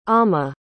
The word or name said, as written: ama